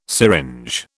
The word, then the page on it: syringe: British English